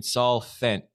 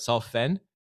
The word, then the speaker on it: Sol Fenne: I'm really excited to be here interviewing Saul Fenne. Did I say that right? Saul Fenne? – Yeah, you did, yeah.